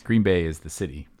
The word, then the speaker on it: Green Bay: It's more of an emphasis on the Bay. – Green Bay. – Green Bay.